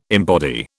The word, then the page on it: embody: British English